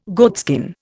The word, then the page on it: goatskin: Indian